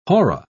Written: hora

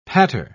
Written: patter

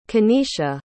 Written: kenisha

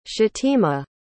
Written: Cha'tima

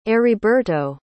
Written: heriberto

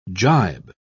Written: gybe

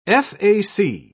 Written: fac

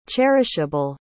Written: cherishable

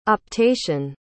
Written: optation

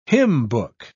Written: hymnbook

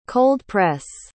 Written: cold-press

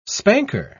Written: spanker

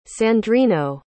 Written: sandrino

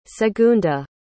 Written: segunda

